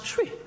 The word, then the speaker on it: Twi: It's spelled T-W-I, but it pronounced Chwi.